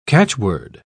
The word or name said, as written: catchword